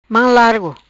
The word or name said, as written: Mam largo